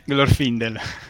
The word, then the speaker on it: Glorfindel: io non riesco a dire la pronuncia, però mi dà la vita. Glorfindel. – Ecco, grazie. È quello di Gondolin che è morto,